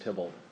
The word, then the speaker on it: Theobald: Spelled Theobald, pronounced Tybalt.